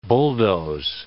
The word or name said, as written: bulldoze